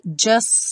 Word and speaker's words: just: Aqui, just singing, ficou como se fossem dois S's. O T do just não é pronunciado.